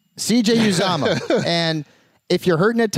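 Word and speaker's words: Uzomah: This is how it's pronounced. CJ? – Uzama. – Uzama. Uzama. – Yep.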